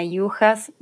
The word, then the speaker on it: Juhasz: Bueno, mi nombre es Mariana Yujas. No espero que se aprenda mi apellido porque es muy difícil. se pronuncia de una manera, se escribe de otra.